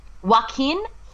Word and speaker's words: Joaquin: Number one is, it's Joaquin. Is that how you pronounce his name? – Joaquin Phoenix will get the Oscar.